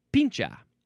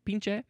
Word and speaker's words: pinche: We got pincha. Is that how you say it? Pinche? Pinche? P-I-N-C-H-E.